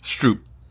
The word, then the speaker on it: Stroop: How is it pronounced? – Stroop. – Oh, shit, for the jeez. Stroop. Ah, oh, man. That would have been like my sixth guess.